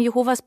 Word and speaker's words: Jehova: Als Zeugen Jehovas bevorzugen wir jetzt die Aussprache Jehova.